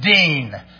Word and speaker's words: Dean: My last name is Dean, D-E-A-N.